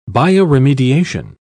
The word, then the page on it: bioremediation: British English